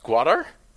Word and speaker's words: Gwadar: Yeah, so a new island appeared off the coast of, I forget how it's pronounced, Gwadar? In Qatar? – September? No, G-W-A-D-A-R.